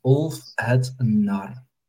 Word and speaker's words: Úlfhédnar: So that's Úlfhédnar.